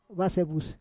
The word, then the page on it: vasevus: Roon